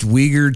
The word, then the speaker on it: Uyghur: Uyghur, that's right. I keep forgetting we say that wrong every time. – It's Uyghur. – It's Uyghur, uh-huh.